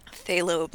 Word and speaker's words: phthalo: Phthalo blue. – Phthalo blue. Oh, it has a P. It has a silent P.